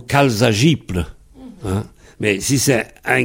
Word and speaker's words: jibl: J-I-B-L. Du sable, gible. Le B, ça prononce P en final, donc ça fait gible, mais ça s'écrit gible, comme pauple, qui s'écrit pauble, quand on a beauple.